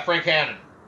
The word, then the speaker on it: Hannon: Yeah, Frank Hannon, yeah.